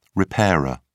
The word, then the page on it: repairer: British English